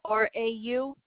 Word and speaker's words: Radabaugh: Q-U-E. What's your last name? – Radaba. RadabAugh. – A-U-G-H.